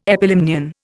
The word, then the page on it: epilimnion: Indian